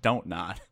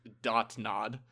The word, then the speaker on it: Don't Nod: Yeah, see, the word Sweet. Don't and then the word nod, when you put them together, you pronounce it Don't Nod. – I thought it was Dot Nod.